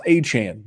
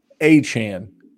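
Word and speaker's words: Achane: So you're hoping for that kind of upside with him, but speed, you got to be up, stock up, and it's pronounced Achan, Devon Achan. Kevin, get it right. You're the producer. You should have told me.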